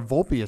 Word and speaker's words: Volpe: Am I saying it right with Volpe? – Yeah, Anthony Volpe.